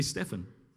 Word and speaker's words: Stephen: Listen to what, it's actually Stephen. That's how you pronounce his name. Thank you. I got it right.